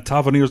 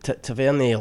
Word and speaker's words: Tavernier: I think you're still using the French pronunciation. – I never know. – Tavernier. – Right? – Not the French Tavernier.